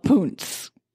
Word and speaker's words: Poonts: So there's this new item, and it's called Poontz. – Poontz? Please spell that. Is it P-O-O-N-T-Z? – P-O-O-N-T-S.